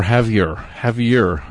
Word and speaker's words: Javier Escovedo: But he's singing about the true believers down in Austin, Texas, and having a beer with Javier Escovedo. Or Javier, I believe. I believe you do pronounce the R.